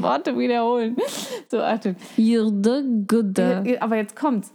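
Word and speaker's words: jord: Güde.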